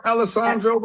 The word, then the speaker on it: Alessandro: So, your new album, The Book of Alejandro. Am I saying that right? – Alessandro. – Alessandro? – We'll take the J. – Okay. – you said Alejandro, but it's Alessandro.